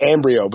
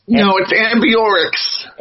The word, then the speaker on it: Ambiorix: I say it Ambrio, but that's not right. – It's Ambiorix.